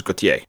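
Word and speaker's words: Gotye: It's a phonetic pronunciation of G-A-U-T-H-I-E-R, which is how his name is really spelled. – All right. – And that's pronounced in French as Gautier.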